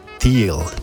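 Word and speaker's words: Thile: No sé si conocen a Chris, bueno, se pronuncia Thiel, por cierto.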